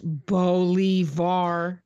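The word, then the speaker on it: Bolívar: The central plaza is dedicated to Simon Bolivar, pronounced Bolivar, the liberator who played.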